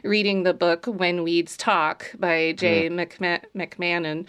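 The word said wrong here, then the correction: McCaman: I'm sorry, probably saying that wrong. But McCammon.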